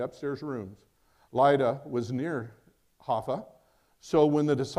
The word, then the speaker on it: Jaffa: In Jaffa, it looks like Joppa, but it's Haffa. That's really how you say it, Haffa.